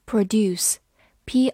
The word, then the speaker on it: produce: produce，p r o d u c e，produce，p r o 发 pro，d u do c e s，produce，produce。美式发音字母 u 发 u 这个长音，produce 也是可以的。